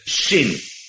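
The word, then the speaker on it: Sin (שׂין): En nou is het zo, als er hier aan de rechterkant een puntje boven staat, dan wordt die uitgesproken als SJ of SCH, Shin. En als die aan de linkerkant staat, dan is het een Sin. Dus zonder J erachter.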